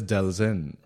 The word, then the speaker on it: Delzin: Like nobody ever pronounces my last name right the first time. – Now we want to know. – My last name, and it's not that crazy. My last name is Delzin, right?